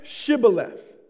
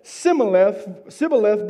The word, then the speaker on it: Shibboleth: When they said no, they said to him, then say Shibboleth. Then say Shibboleth. And he said Sibboleth before he could not pronounce it correctly.